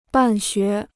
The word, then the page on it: 办学: bàn xué